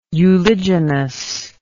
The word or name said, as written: uliginous